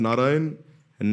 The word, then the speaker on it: Narayana: But then because he uttered the name of Narayan, and Narayan has 4 syllables, Narayana, 4 Vishnu Dutas appeared.